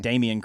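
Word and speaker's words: Dameyune: Dominion Craig from Auburn. – Damian Craig.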